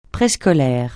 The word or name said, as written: préscolaire